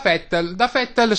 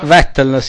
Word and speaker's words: Vettel: Da uno, da Vettel sulla Toro Rosso motorizzata Ferrari, che aveva compiuto il miracolo, essendo un bravissimo pilota sul bagnato in quel momento pioveva. – Vettel, sì, non si pronuncia con la V,